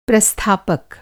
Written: प्रस्थापक

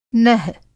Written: नह

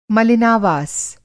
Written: मलिनावास

